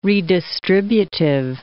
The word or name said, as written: redistributive